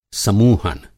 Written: समूहन

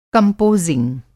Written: कंपोज़िंग

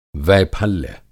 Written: वैफल्य

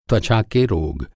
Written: त्वचा

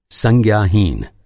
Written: संज्ञाहीन

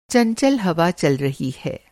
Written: चंचल

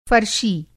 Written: फ़रशी

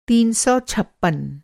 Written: तीन सौ छप्पन

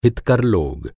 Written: हितकर

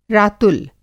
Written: रातुल

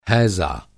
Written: हैज़ा

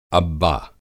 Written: अब्बा

